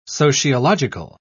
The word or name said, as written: sociological